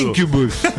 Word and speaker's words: Incubus: Incubus.